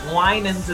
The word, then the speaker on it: Winans: Just to check, I've heard different people pronounce your surname differently, so I'm saying Winans. Am I saying that right? – You're right. Winans is it.